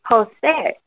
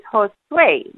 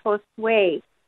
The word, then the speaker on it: Josué: You know, and I pay masses for him, and there for a while they were pronouncing his name Jose. And so I had to, you know, go and talk to the lectors and the priest that my dad's name is Josué, you know, like when you sway to the side. And so now they pronounce his name right, Josué.